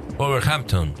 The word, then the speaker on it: Wolverhampton: Wolverhampton.